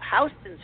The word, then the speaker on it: Houston: Houston Street is pronounced Houston, even though it's spelled like Houston, the city.